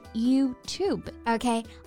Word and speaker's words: YouTube: Yes, and YouTube is a compound word made up of U and tube, so it's important to pronounce each syllable separately. 所以它的正确发音应该是 YouTube。 – Okay, I think that's all the expressions we have for today.